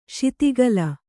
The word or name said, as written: ಶಿತಿಗಲ